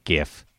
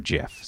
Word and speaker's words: GIF: I think GIF has been the prevailing pronunciation for a long time, but the actual inventor of the graphics interchange format, or GIF, says that he always pronounced it GIF. So he says that's how it should be pronounced.